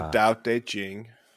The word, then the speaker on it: Dao De Jing: Oh, that's from the Dao De Jing. – Yeah. – Dao De T. It's their T's or D's. Their T's are pronounced like D's.